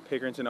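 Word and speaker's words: Pickerington: So you're born in Pickerton, is that how you say it? – Yes, I'm from Pickerton, Ohio.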